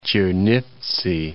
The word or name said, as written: Chernivtsi